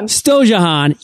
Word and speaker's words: Storjohann: Storjohann.